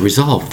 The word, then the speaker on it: resolve: Let's fix it properly and get a solution to resolve this problem. That is the word resolve. R-E-S-O-L-V-E. Resolve. Notice the word resolve. The S sounds like a Z, doesn't it?